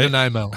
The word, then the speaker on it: Nanaimo: Nanaimo.